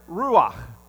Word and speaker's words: ruach: But that word Spirit, and it's all throughout the Old Testament, it's the Hebrew word ruach, all right? And you got to say it like that. You got to go, okay? Like you got something caught in the back of your throat, you know what I'm saying?